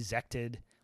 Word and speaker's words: Zhcted: So basically there's these two countries, Brune and the other country makes no, it's spelled Zhcted, Zected. We'll call it Zected just because I have to say it a bunch of times.